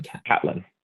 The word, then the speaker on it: Catelyn: And now we get to Caitlin, I think you pronounce it Catlin. – Catelyn. Yeah, I Catlin. – usually say Catelyn because she gets called Cat a lot, so.